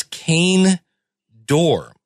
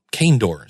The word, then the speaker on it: Cainedorr: And I'm going to pronounce this Kane Dorr. It's CAINEDORR. And Kane Dorr sounds like a planet in Star Wars.